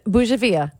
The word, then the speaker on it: bougainvillea: But now say the other word, bougainvillea. – Bougainvillea. – Now you got it, but you were saying like bouillabaisse.